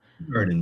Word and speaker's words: Hoegaarden: And I was mixing a good old fashioned Hoegaarden, or as it's spelled, Hoegaarden. But as they tell you on the box, that you pronounce it with a who and not a ho. Who's the ho? – Hoegaarden, man.